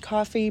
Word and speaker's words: Ko-fi: Well, it's K-O-F-I. And I'm pretty sure it's pronounced coffee because their whole shtick is that you give folks a cup of coffee.